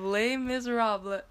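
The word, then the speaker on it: Les Misérables: She invited me to see a live play of Les Miserables.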